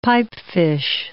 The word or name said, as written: pipefish